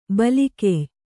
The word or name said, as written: ಬಲಗೆಯ್